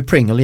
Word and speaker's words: Pringle: Ja, nej, men hur man säger, och det finns egentligen bara två stycken uttal på den, på Martin Pringle eller Pringle. – Är det engelskt eller? – Det är ju Pringle egentligen. – Ja.